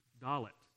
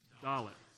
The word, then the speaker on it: Dalet: Dalet. So say that with me, Dalet. All right, so let's say our four together. We have Aleph, Beit, Gimel, and Dalet.